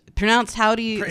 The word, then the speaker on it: Hovvdy: This is Howdy, pronounced Howdy, spelled H-O-V-V-D-Y.